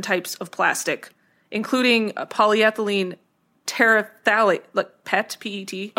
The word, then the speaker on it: terephthalate: Terephthalate, yeah. No, that's it.